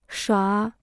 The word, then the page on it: 耍: shuǎ